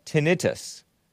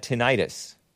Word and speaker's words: tinnitus: Love Zoda does not like juice says it is pronounced tinnitus, not tinnitus. Itis means inflammation, which has nothing to do with ringing of the ear syndrome.